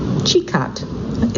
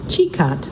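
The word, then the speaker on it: Čikat: The jewel of Losinj, and by the way, that's spelled L-O-S-I-N-J, is Sikat, Chikat. It's nice, he's giving me pronunciations as I go. So the jewel of Losinj is Chikat, C-I-K-A-T,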